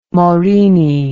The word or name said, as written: Morini